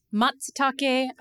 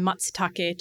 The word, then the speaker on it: matsutake: She also says that you have to sort of spit the t in matsutake to pronounce it correctly.